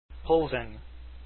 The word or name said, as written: plzen